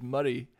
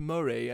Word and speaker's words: Moray: No, yes, it is Murray. It's so annoying, like, when people move here for the first time, like, oh, yes, we moved to Moray, and you go, oh, so close.